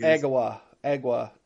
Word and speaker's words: Agawa: Agawa, I think. – Agawa. A-G-A-W-A Canyon.